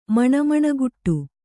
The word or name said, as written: ಮಣಮಣಗುಟ್ಟು